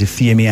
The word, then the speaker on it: Ifiemi: My name is Ifeyemi Andrew. Ifeyemi Andrew on Twitter, Ifeyemi Andrew Official on Facebook and on Instagram.